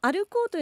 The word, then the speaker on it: ALKOO: はい、この歩こうというのは ALKOO と書いて歩こうです。